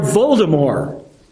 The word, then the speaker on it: Voldemort: Lord Voldemort is a foil for Harry Potter. And by the way, Voldemort is the correct pronunciation.